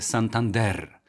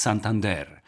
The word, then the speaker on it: Santander: Mój szef, który jest iberystą, powiedział mi, że powinno mówić się Santander z akcentem na ostatnią sylabę. Tak jakby po francusku człowiek mówił jak Pascal Brodnicki, mój przyjaciel, który ma tę uroczą manierę mówiąc w języku polskim, że akcentuje ostatnie sylaby. No więc to jest Santander.